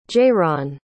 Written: jayronn

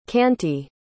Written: kanti

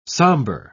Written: somber

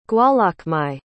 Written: gwalachmai

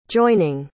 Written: joining

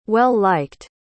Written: well-liked

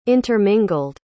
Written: intermingled

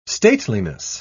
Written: stateliness

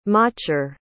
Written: machar

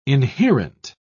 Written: inherent

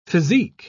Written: physique

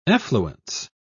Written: effluence

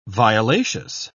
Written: violaceous

